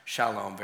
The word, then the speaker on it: shalom: It's salom or shalom. Most people, obviously, it's pronounced shalom very often.